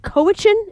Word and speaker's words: Cowichan: The kid was 7, and it was right outside their family home in Lake Cowichan.